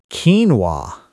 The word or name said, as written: quinoa